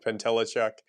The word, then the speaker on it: Pentelechuk: So my name is Nick Pantelichuk.